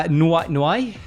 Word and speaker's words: Noé: But there were some cool stories, including Mr. Noé. – That's how you say it in Italian.